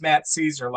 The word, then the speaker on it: Szczur: And I was talking about Matt Caesar, S-Z-C-Z-U-R, and I kept calling him Scherzer, because it sounds like Max Scherzer, so my brain was kind of messing up. Matt Caesar is pronounced Matt Caesar, like, you know, Caesar, Hail Caesar.